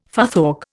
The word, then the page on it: futhorc: Indian